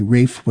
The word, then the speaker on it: Rafe: All of his sons were so named, and occasionally, at school or in the army, Rafe was called Williams, but that is not correct. Also, Rafe's name was pronounced R-A-F-E, and any other pronunciation used to infuriate him.